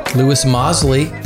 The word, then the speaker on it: Lewis Mosley: Lewis Mosley.